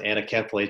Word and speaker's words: anencephaly: Our first child died of a condition called anencephaly, or some pronounce it anencephaly.